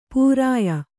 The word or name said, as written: ಪೂರಾಯ